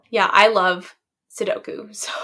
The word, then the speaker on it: Sudoku: No, Sudoku. – Sudoku, yeah.